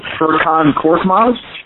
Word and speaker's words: Furkan Korkmaz: Furkan Korkmaz. You just call him Fur. No, you, said, you said that right.